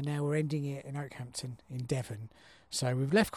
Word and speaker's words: Okehampton: Now I want to call it Ockyhampton or Okeyhampton, Ockyhampton, um, but it's not pronounced that. I have a reliable source um, of a friend uh, and um, temporary line manager called Kat who knows she's from this neck of the woods, so she knows it's called um, Okehampton.